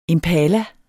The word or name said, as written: impala